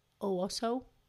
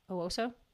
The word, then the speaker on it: Owosso: She's about 100 miles away in Oshawa, Michigan. That's not right. Owosso. – Yeah, I like that. – I don't know the pronunciation. It's O-W-O-S-S-O. Owosso? – Sure. – Owosso. We're going to go with Owosso, Michigan.